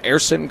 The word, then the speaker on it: Gerson: Everybody was walking off the field following the pitcher, Erson Garabito. Garabito's first name and last name both begin with a G, but the G is silent in his first name, Erson, G-E-R-S-O-N. Garabito is G-A-R-A-B-I-T-O.